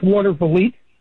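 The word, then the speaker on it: Watervliet: How do you pronounce that? – It's water volite. – I was close. – It's a Dutch word.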